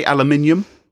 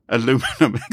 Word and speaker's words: aluminium: Or how do you say aluminium in America? – Aluminum. – Exactly.